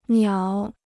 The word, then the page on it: 鸟: niǎo